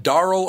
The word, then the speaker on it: Daryl: Katie Daryl do you pronounce it Darrell or Daryl? – Daryl.